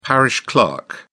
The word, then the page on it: parish clerk: British English